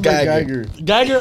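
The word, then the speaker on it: Amber Guyger: Have you guys heard about the Amber Gregor situation? Hey, did I even say it right or not? – It's Amber Geiger.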